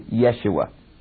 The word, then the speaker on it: Yeshua: Now, the word Jesus really is pronounced in Hebrew, Yeshua.